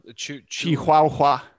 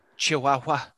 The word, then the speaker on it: Chihuahua: Chihuahua. – Chihuahua.